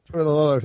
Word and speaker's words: Toilolo: Toilolo.